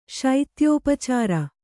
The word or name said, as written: ಶೈತ್ಯೋಪಚಾರ